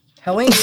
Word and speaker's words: Helene: Helene, yeah.